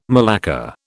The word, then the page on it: malacca: British English